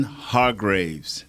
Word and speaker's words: Hargrave: And Javon Hargraves, I'm pronouncing that right, correct? Number 97. – Mm-hmm. Is it Hargrove? – Hargrave.